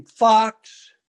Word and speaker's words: Fox: Last name Fox. Excuse me. One X. I'm against silent letters, so that works out.